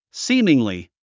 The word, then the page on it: seemingly: síːmiŋli; スィーミングリィ